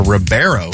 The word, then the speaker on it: Ribeiro: Or Ribeiro is how you say it. – Is it? – Wow, it is not spelled that way. – It's R-I-B-I-E-R-O. – R-I-B-E-I-R-O. – Oh, Ribeiro. – I before E.